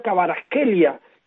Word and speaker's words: Kvaratskhelia: Un Napoli que tiene un nombre raro, un nombre que es de Georgia y que se pronuncia Kavicha Kvaratskhelia, que es está llamado a ser el MVP de la Serie A.